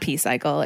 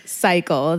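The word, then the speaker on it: Psycle: So it's not P-Cycle, it's Cycle. The P is silent.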